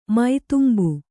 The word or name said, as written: ಮೈದುಂಬು